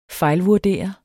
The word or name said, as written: fejlvurdere